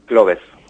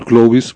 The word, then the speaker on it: CLOVES: ¿Cómo pronunciamos el síndrome? – Cloves. – Cloves, perfecto. Iba a decir Clovis, pues no, Cloves.